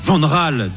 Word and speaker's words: Van Gaal: Avis de Kevin, attention aux Pays-Bas pragmatiques de Louis Deschamps Van Gaal. Van Raal, devrais-je dire, si vous prononcez à la néerlandaise, hein, puisqu'on nous l'impose désormais.